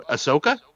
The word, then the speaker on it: Ahsoka: Oh, Ahsoka? – Thank you.